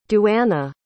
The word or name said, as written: duana